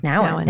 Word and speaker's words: Nouwen: from um, I always say his last name wrong. – Henri Nouwen. – Nouwen. There we go. Nouwen.